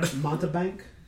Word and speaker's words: mountebank: How do you pronounce that? – I don't know. I've only read it on the card. – Mountebank? – That's the one.